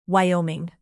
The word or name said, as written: wyoming